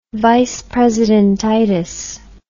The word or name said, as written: vice presidentitis